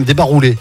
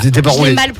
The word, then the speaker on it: débarroulé: Gaga ou occitan, pardon, débarrouillé. – Jamais entendu. – Si, on dit même débarroulé si c'est gaga. – Alors c'est gaga. – C'est gaga. – Débarroulé. J'ai mal prononcé.